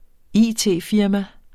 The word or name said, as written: it-firma